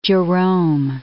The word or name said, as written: Jerome